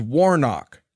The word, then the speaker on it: Warnock: By the way, the man's name is Warnock.